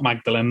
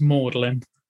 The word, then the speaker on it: Magdalene: No, that is not Magdalene. That is Magdalene. – No, it's not. – It is. – Because it's an M-A-G. It makes a more sound. – Yeah, it's pronounced Magdalene. – Are these Magdalene Welsh? – Magdalene College, Oxford.